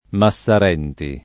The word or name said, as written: Massarenti